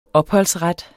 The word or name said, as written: opholdsret